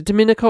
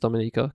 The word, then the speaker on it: Dominica: Sitting in his paint and dust strewn studio, he explains how his childhood in Is it Dominica or Dominica? I forget how to say it. I think it's Dominica, because Dominican Republic and Dominica, I think that's how you do it.